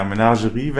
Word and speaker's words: Menagerie: Wieder der gute Mengari, wie wird das ausgesprochen? Mengarin, Wächterin? – Menagerie, ich habe mich für uns gebracht. – Ja, Menagerie, Wächterin.